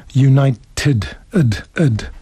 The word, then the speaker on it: United: Yeah, you should say United, ed.